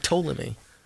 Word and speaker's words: Ptolemy: Like Ptolemy. Anyway, okay. What Ptolemy is? – Yeah, the P is silent.